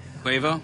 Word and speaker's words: Quavo: Quavo? – Well, I guess the phonetic spelling is not phonetically spelled properly. Kwei-VO. – No, that's Quavo. – Yeah.